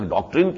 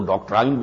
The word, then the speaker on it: ڈاکٹرائن: جسے ہم تو اس کا تلفظ ابھی تک ڈاکٹرین کیا کرتے تھے لیکن آج کل وہ ڈاکٹرائن بن گیا ہے،